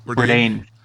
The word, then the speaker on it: Bourdain: Bourdain.